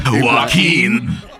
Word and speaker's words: Joaquin: So it was like Nina takes Joe Quim. How the fuck do you say his name? – Joaquin. – Joaquin. Oh, okay, we're going with that. Okay.